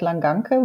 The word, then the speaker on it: Langanke: My name is Harriet, Harriet Langanke when it comes to German pronunciation, but um, to an international audience, Harriet is perfectly fine.